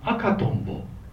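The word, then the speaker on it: 赤とんぼ: 喋りの仕事をやっておりますといろいろとアクセントにこだわりを持つんですが、この赤トンボというのがどうやらアクセントとしては正解のようです。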